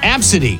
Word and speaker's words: Abcde: the name is A-B-C-D-E, but it's pronounced Absidy. Absidy, but it's A-B-C-D-E.